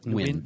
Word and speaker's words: Nguyen: Page 204 has Descender number 1 by Jeff Lemire, art and cover by Dustin Nguyen. Did I say that correctly? – Nguyen. – More like Nguyen. – Nguyen, thank you.